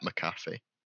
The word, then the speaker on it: McAfee: Pat McAfee. – Yeah. – How the fuck do you pronounce his last name? – McAfee. I thought you were right the first time. – I thought it was McAfee, yeah.